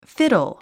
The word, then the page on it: fiddle: fídl; フィドォ